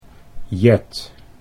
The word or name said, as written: Jet